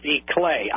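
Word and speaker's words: Iklé: It's pronounced Ickle. I-K-L-E. Mm-hmm. – Ickle. – Right. – Okay. His sister was named Coppa, K-O-P-P-E.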